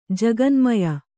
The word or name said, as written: ಜಗನ್ಮಯ